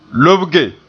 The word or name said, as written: lobge